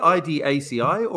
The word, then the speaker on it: IDACI: Is it called, I don't know, is it IDACI, or is it pronounced altogether as one word? What is it called? – That's the question I get asked the most. it's called Idaci Motorsport.